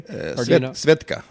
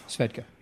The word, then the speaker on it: Svedka: Am I pronouncing it correctly? – Svedka. – Svedka, yeah.